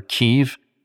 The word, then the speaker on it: Kyiv: Now, you're hearing about Kiev on the news, unfortunately. Kiev or Kyiv, the Ukrainians pronounce it Kyiv.